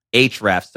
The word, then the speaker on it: Ahrefs: Ahrefs. So it's spelled Ahrefs.